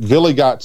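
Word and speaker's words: Wiligut: But Villigot, spelled with a W, of course, because there's that German pronunciation.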